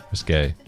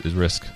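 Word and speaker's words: Riske: How would you say it? Risk. – Risque. – Well, that's it. – There should be a little accent on the E. – Yeah, well, there's no accent. It's Risk.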